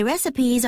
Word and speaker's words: recipe: R-E-C-I-P-E. 발음 주의하세요. Recipe가 아니고 recipe예요.